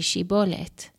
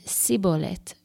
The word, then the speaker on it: Shibboleth: If he said, "No," then they said to him, "Now say Shibboleth." And he said, "Sibboleth," for he could not manage to pronounce it correctly.